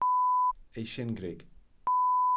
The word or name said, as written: eisingrug